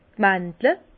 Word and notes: d' Mandle: Bas Rhin; Herrlisheim

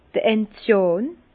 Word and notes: d'r Enziàn: Bas-Rhin; Herrlisheim

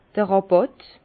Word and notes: d'r Ràbàtt: Alsatian; Bas-Rhin; Herrlisheim

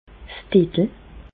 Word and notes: 's Diddel: Bas-Rhin; Reichshoffen

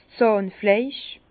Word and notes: 's Zàhnfleisch: Bas-Rhin; Herrlisheim

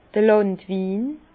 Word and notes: d'r Làndwin: Bas-Rhin; Herrlisheim

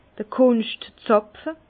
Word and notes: d'r Kunschtzàpfe: Bas-Rhin; Herrlisheim

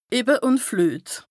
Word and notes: Ebbe ùn Fluet: Bas-Rhin; Strasbourg

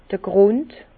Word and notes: d'r Grùnd: Bas Rhin; Herrlisheim

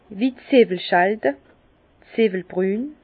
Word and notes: wie Zzwwelschälde, Zewwelbrûn: Bas-Rhin; Herrlisheim

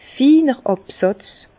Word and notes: finer Àbsàtz: Bas Rhin; Herrlisheim

